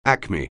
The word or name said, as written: acme